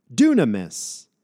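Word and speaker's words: dunamis (δύναμις): Doesn't the first part of that word sound somewhat like the English word dynamite? We get our English word dynamite from this Greek word dunamis.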